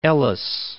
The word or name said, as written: Ellis Island